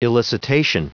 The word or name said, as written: elicitation